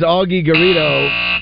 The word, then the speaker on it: Augie Garrido: That is Augie Garrido. I think that's pronounced his name. – Yeah, that's correct.